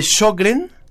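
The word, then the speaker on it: Sjögren: ¿Es el síndrome de Sjogren? – Eso es, creo que lo has pronunciado bien.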